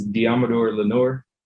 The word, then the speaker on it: Deommodore Lenoir: So it's Deommodore Lenoir. That's how you say it.